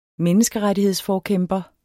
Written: menneskerettighedsforkæmper

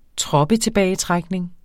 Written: troppetilbagetrækning

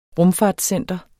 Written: rumfartscenter

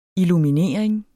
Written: illuminering

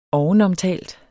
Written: ovenomtalt

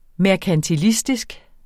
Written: merkantilistisk

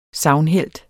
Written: sagnhelt

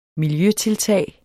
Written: miljøtiltag

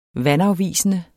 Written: vandafvisende

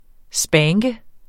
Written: spanke

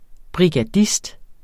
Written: brigadist

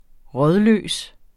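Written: RÅDLØST

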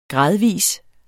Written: gradvis

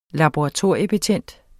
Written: laboratoriebetjent

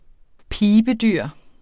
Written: PIVEDYR

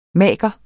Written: MAGERENS

